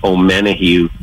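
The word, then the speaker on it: Omenihu: Charles, I'm going to mispronounce his last name, O'Menahue. – You nailed it.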